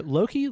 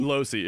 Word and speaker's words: Losey: Loki? Losey? – Losey. It's Loki. Losey? Jeremy Losey. That's how you say it.